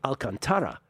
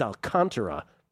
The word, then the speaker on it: Alcantara: I got this one wrong because I thought I was being smart with the true Spanish pronunciation should be Alcantara, yeah. – But he purposely puts the accent in a different place, so you know that that's not the correct pronunciation.